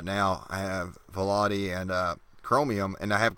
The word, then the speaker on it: Vivaldi: You can install the Pepperflash on Vivaldi. I keep pronouncing it differently every time. – Vivaldi.